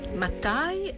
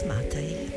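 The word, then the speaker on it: Matthay: Dunque, come pronunciare Tobias o Tobias? Matai alla tedesca, Matai nella pronuncia inglese o addirittura Matte? Sceglieremo Tobias Matai, anche se il nome con cui confidenzialmente veniva chiamato da chi lo frequentava era Uncle Tops, zio Tops.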